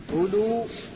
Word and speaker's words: أولو: أولو انظر هنا، أولو هذه أولو عند الرفع وأولي عند النصب أو الجر. الواو هذه ما تنطق لكن تكتب.